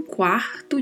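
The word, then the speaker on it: cuarto: Quarto, que aqui no contexto da nossa frase refere-se a hora, é um quarto de hora, ou seja, 15 minutos. A pronúncia de quarto é com este R bem vibrato, né? Quarto. E é bem pronunciada a primeira sílaba, quarto.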